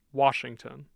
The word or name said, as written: Washington